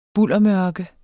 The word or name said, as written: buldermørke